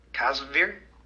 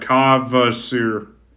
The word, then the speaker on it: Kvasir: Kvasir? – Yeah, Kvasir. – Kvasir.